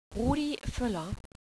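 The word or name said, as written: Völler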